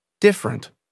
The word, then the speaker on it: different: The vowel immediately after a stressed syllable is sometimes not pronounced. – Average. – Different.